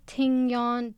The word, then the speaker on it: Tignon: Tignon, T-I-G-N-O-N. Tignon.